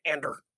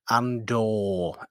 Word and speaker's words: Andor: And then his just great pronunciation of Cassian Ander. – the Swedish comes out there. – Yes, it comes out very much. Yes. – That's not how you pronounce it. It's Andor.